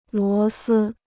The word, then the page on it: 螺丝: luó sī